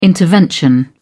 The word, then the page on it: intervention: American English